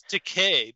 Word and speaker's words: Takei: It's Takei because it rhymes with a toupee.